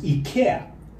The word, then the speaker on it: IKEA: In Swedish, it's pronounced IKEA.